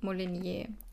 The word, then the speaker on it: Molinier: M-O-L-I-N-I-E. – Molinier.